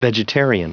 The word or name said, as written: vegetarian